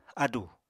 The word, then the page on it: ádù: apatani